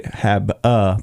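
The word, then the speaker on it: Habe: So it's actually Hab-a, but there's an E, not an A. So H-A-B-E-D-A-N-K.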